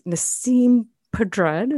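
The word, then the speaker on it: Nasim Pedrad: And my staff pick is Nassim Pedrad. Is that how you say it, Jeff? – Yeah.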